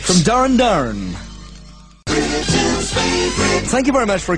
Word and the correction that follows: Duran Duran: a couple of people who rang up and I didn't realise that I said it, but I mispronounced the name. I said Darren Darren and Planet Earth at number 26, when of course it should be, as everybody knows, Duran Duran.